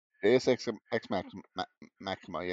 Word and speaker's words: Deus Ex Machina: Is that they use a Deus Ex Machina. And I hope I am correcting that, pronouncing that correctly. – Mm-hmm. Yeah. Deus Ex Machina.